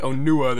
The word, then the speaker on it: Onua: So, like, Onua, there's more stress on the U.